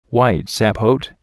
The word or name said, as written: white sapote